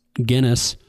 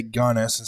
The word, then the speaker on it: Gunness: Both of us thought it was Guinness. And we both pronounce that. I still fight to say Gunness instead of Guinness. – Yeah. – My mouth wants to say Guinness.